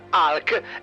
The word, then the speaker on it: Hulk: Hulk, pronuncia corretta Hulk, è l'alter ego verde e rabbioso di uno scienziato, il dottor Robert Bruce Banner, timido e riservato.